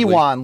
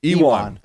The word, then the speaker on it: Ion: Ion Cutelaba, or however you pronounce his first name, I can never remember correctly. – Ewan. – Ewan. That's right.